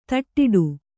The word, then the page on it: ದಡ್ಡಿ: daḍḍi